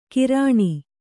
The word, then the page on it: ಕಿರಾಣಿ: Kannada